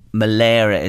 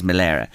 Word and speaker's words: Mullery: Malera is the right pronunciation. It's spelt Mullery, but it is Malera.